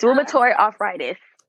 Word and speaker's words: rheumatoid arthritis: Yes, rheumatoid arthritis.